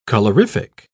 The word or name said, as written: colorific